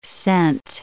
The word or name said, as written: cent